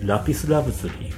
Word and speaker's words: lapis lazuli: and the lighting color was lapis lazuli. How to say lapis lazuli in correct pronunciation? – Lapis lazuli. – Yes. Because the character, that was a song from Sacred Seven, and the character, Nakajima-san was played.